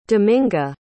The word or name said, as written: dominga